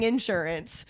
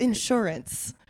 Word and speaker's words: insurance: She got mad at me for saying insurance. She was like, it's insurance. – Car the insurance. Emphasis, the emphasis. I say insurance. The emphasis was on the wrong syllable.